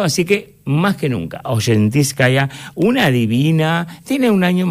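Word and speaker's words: Osetinskaya: La S, como si fuera SH. – Oshentiskaya.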